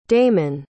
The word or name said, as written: damen